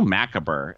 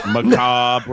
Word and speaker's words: macabre: Last year at Christmas, I was telling my mom, it's so macabre. And she said, macabre. No, right. Macabre. – Macabre. Is that a rapper? – I was like, that's how it's spelled. And she's like, well, it's not how it's said.